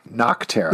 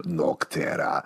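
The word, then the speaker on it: Nocterra: Did you say Not Terra? Don't pronounce it weird. – Noctera. – Thank you. – Noctera.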